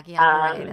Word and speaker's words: Guillain-Barré: And that's the point at which they were finally able to diagnose what then we called Guillain-Barré. – Yeah, Guillain-Barré. That's how you say it.